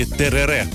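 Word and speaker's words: tereré: Перелетаем с вами в Парагвай, и там нам уже подают с вами тереré. Вот именно так, с ударением на последний слог.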